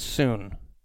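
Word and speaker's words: soon (σύν): And it's pronounced soon. S-U-N in English. The transliteration, soon.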